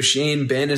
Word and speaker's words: Xie Bin: If you're looking to bet that, your sports book, it's actually spelled XIE space BIN. So Shane Bin is his name, but that's how it's spelled, XIE BIN, -155.